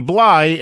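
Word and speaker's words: Blythe: And Lamb of God and their singer Randy Bly, and that is how you properly pronounce his last name, by the way, tends to scream a little bit more than sing.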